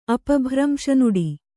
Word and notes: ಅಪಭ್ರಂಶನುಡಿ: apabhramśa nuḍi

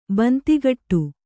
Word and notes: ಬೊಂತೆಕಟ್ಟು: bonte kaṭṭu

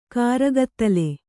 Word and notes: ಕರ್ಗತ್ತಲೆ: kargattala

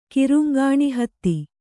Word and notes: ಕಿರುಂಗಾಣಿಹತ್ತಿ: kiruŋgāṇI hatti

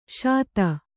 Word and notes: ಶಿತಿ: śiti

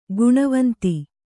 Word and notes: ಘನವಂತ: kannada

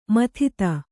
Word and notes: ಮದದ್: Kannada

